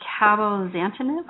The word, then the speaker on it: cabozantinib: It's called cabozantinib. I'm probably not saying that right. – You're saying it correctly.